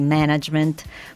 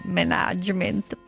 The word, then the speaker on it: management: Approfitto per ricordare la pronuncia corretta di management, forse una delle parole che si fa più fatica a pronunciare in Italia. Non management, please, management. Management, così, decisi.